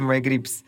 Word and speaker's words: MyGribs: Я думаю, на какую правильную букву ударения у нас ставится. Наверное, все-таки MyGrips. MyGrips.